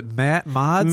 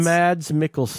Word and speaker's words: Mads Mikkelsen: And how do we pronounce the actor? Is it Matt Mods? – Mads Mikkelsen. – That's what I thought. I had to be getting that wrong.